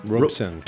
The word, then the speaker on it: Robeson: We're live in studio with Greg Roberson? – Robeson. – Robeson.